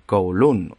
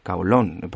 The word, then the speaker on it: Kowloon: Bueno, como habréis podido leer en el título del episodio, hoy venimos a hablar de, de Kowloon o Kowloon, pronunciado en cantonés.